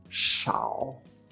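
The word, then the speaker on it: shao (少): Pues SHAO, con tercer tono, shao.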